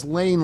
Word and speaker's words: Lein: It's spelled L-E-I-N and says Lane like Vane, and it was confusing.